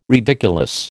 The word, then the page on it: ridiculous: American English